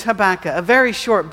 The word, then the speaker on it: Habakkuk: Originally, the plan was to read Habakkuk, and again, it looks like Habakkuk, but it's pronounced Habakkuk, and also Obadiah.